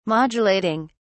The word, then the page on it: modulating: British English